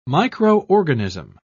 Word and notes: micro-organism: British English

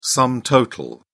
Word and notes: sum total: British English

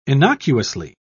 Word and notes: innocuously: British English

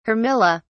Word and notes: armilla: British English

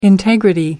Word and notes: integrity: American English

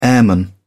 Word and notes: airman: British English

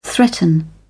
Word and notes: threaten: British English

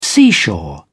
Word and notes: seashore: British English

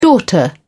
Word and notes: daughter: British English

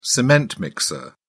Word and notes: cement mixer: British English

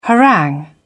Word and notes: harangue: British English

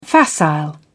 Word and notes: facile: British English

